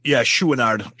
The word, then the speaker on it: Chouinard: How do you say his last name again? – Chouinard, right? – Chouinard? – Chouinard.